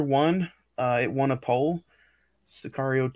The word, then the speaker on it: Sicario: I think it's pronounced Cesario. Am I remembering that wrong or right? – Sicario.